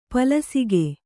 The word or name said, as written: ಪಲಸಿಗೆ